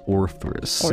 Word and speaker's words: Orthrus: It's spelled O-T-H-R-Y-S, so I think... – Orthrus.